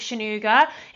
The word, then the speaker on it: Sonuga: You can catch me on Instagram at Rebecca Shanuga. It's spelt at, obviously, the curly at sign, Rebecca, R-E-B-E-C-C-A, and then my surname, S-O-N-U-G-A.